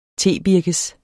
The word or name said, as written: tebirkes